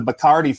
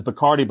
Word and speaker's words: Bacardi: And the Bacardi family, we know them as Bacardi, but in Spanish it's called Bacardi.